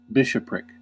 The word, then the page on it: bishopric: American English